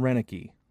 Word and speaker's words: Roenicke: Ron Roenicke. Oh, Re- that's his name, Roenicke. That's him, Roenicke. Okay.